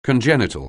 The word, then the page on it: congenital: anglais